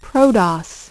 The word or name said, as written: ProDOS